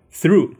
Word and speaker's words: through: go through through，注意这个发音，t h through，其实就是说 experience。